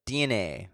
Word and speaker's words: DeNA: Yeah, so they being Nintendo, came out yesterday and confirmed that it will partner with DNA. This is spelled D-E-N-A.